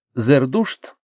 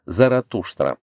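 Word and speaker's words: зердушт (زردشت): Здесь «зердушт» я произношу по-новоперсидски, по-древнеперсидски будет «заратуштра». Уроженец города Бальха, это на самом востоке Ирана, объявил, что дело не в том, чтобы разобраться, из чего состоит мир.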